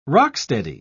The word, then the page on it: rock-steady: British English